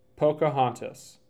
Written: Pocahontas

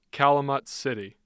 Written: calumet city

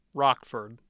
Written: Rockford

